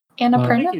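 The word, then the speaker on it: Annapurna: I can never pronounce that name correctly. – Anna Perina? – You got it. – Perina. Okay. I always feel like I'm mispronouncing it.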